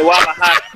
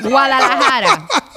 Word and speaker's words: Guadalajara: I'm Mexican de Guadalajara. – Oh, de Guadalajara. You didn't even say that right, bro. Guadalajara. Let's get it right.